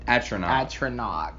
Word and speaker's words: Atronach: Atronach, correct.